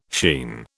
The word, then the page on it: sheen: British English